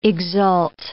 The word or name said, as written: exult